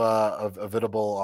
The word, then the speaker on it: Avitable: And then I'm also Avitable on, it's just my last name on Instagram and Twitter.